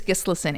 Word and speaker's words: Gisleson: It's Gisleson, Anne Gisleson.